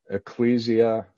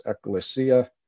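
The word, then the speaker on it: ecclesia (ἐκκλησία): The word church comes from the Greek, ecclesia, some people pronounce ekklesia, which means those who are called out.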